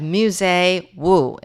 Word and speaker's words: Musee Wu: And with me in the studio, I have Musee Wu.